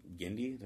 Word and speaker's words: Genndy: I look forward to anything Genndy, did I say his name? – Mm-hmm.